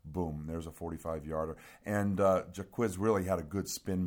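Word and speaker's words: Jacquizz: So, Jaquizz, that's how you say it, yeah. Jaquizz.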